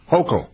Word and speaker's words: Hochul: Was Governor Hochul, is that how you pronounce her name? Was she a lawyer before? – Hochul.